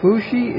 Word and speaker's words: Fuxi: Maybe I'm not pronouncing Fohi correctly, but Fushi is the correct pronunciation for his name.